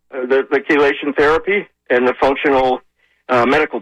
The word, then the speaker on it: chelation: You need, and what I'm talking about is chelation therapy. That's C-H-E-L-A-T-I-O-N, chelation therapy.